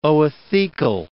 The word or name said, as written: oothecal